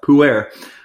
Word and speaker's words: pu'er: It was pu'er. I was just bamboozled by that word for the longest time, and then I found out it's pronounced pu'er, which is pretty funny, and it's on its own.